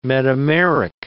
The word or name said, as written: metameric